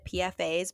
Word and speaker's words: PFAS: They contain PFAS, is how you say it, PFAS, PFAS, which are known as the forever chemical.